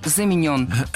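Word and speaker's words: заменён: Вторая ошибка – заменен. Это краткое причастие, и ударение в нем такое же, что и в полном. Замененный – заменен.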